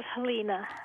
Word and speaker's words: Helena: It is Helena.